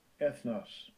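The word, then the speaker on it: ethnos (ἔθνος): Strong's G, 1484, ethnos.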